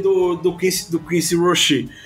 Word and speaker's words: Roche: É, e confirmando, realmente a pronúncia do cara é Rocher. – Quincy Rocher. Belo nome, belo nome. E... É, é só pensar no chocolate.